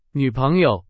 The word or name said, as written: 女朋友